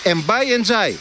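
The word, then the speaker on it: Ndiaye: Όταν ήρθε το πρώτο που τον ρώτησαν, πες μας πώς προφέρεται το όνομά σου. – Ναι. – Και είπε, Εμπάι Εντζάι.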